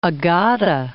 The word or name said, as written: Agade